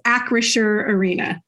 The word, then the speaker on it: Acrisure Arena: Yes, Acrisure Arena.